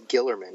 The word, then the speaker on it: Guillermin: I'm not sure how you pronounce his. – I think it's Guillermin, yeah. – Oh, Guillermin, okay.